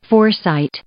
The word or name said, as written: foresight